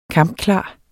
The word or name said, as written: kampklar